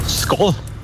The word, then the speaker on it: Skål: Skål. – Wow, richtig? – Echt? – Ja, du hast sogar richtig betont. – Oh. – Wird Skal geschrieben, Skål, aber ausgesprochen.